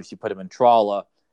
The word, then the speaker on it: Tralla: And in fact, when I was looking at the script last night, every single time Tralla is mentioned in the dialogue, I put in there for the actor to get it right, I put rhymes with Paula.